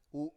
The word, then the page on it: u: Biak